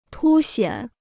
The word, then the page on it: 凸显: tū xiǎn